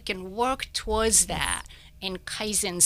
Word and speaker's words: Kaizen: We don't have a lot of time left, but I did want to ask this one question about something called Kaizen. Is that how you pronounce it? – Yes.